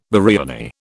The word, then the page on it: biriani: British English